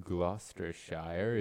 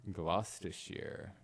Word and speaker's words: Gloucestershire: What we just played was Gloucestershire is Just an Illusion. – Oh, you butchered that. – Oh, I did. – Gloucestershire.